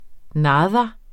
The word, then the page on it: nada: ˈnɑːðɑ; ˈnɑːdɑ